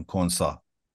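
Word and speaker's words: Konsa: چون داری لبخند بدجنسانه میزنی من ساده تلفظ میکنم، کنسا.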